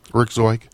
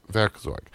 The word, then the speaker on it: Werkzeug: How do you pronounce that? Werkzeug. Yes, Werkzeug.